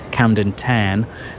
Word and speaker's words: Camden Town: Camden Town, or as it's correctly pronounced, Camden Tan.